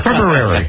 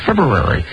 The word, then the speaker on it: February: No, it's February. That's how you pronounce February. Hey, but listen, you're white. It's only... – Didn't we have a discussion, though, that perhaps the R is silent? – We had the discussion.